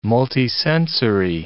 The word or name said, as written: multisensory